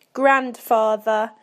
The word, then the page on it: grandfather: British English